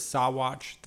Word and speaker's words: Saguache: Like the Sawatch mountain range is technically the same word as Sawatch, the town, except that word is spelled Saguache because it was translated by French people.